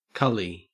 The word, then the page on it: cully: Australian English